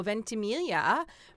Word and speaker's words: Ventimiglia: Uh, Milo Ventimiglia, and that's how you say it, right, Marco? – Yep.